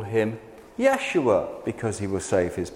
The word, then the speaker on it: Yeshua: Yeshua means salvation. It's pronounced Yeshua.